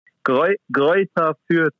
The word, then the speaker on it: Greuther Fürth: Greuther Fürth. – Greuther Fürth.